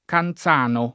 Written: Canzano

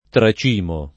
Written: tracimare

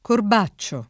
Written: Corbaccio

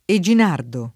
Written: Eginardo

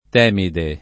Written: Temi

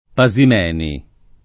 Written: Pasimeni

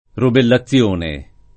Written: rubellazione

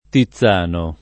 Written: Tizzano

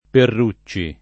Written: Perrucci

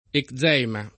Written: eczema

